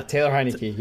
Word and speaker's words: Heinicke: because because they have spoken so highly about uh was it Heinicke? Is that how you say his name? Uh, yeah, Taylor Heinicke.